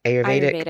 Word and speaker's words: Ayurvedic: Did I say that right? Ayurvedic? – Ayurvedic, yeah.